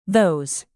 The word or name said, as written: those